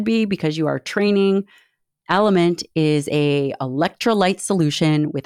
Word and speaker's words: LMNT: This episode is brought to you by Element, pronounced Element, but spelled L-M-N-T.